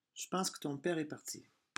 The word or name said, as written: je pense